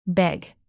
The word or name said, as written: beg